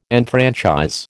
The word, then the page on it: enfranchise: American English